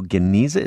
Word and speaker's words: Geniza: You've got here the Jewish world revealed in the Cairo Geniza.